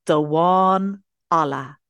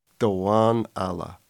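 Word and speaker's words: damhán alla: So it's Dawan Alla. – Dawan Alla. Is that a soft D sound at the beginning? – Yes, exactly. The kind of a.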